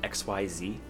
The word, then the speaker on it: Xyz: Xyz is spelled XYZ. – How do you say it? – Xyz.